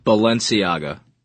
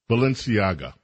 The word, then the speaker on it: Balenciaga: Again, one more time, please. – Balenciaga. – Balenciaga. – Correct. – Thank you. Balenciaga.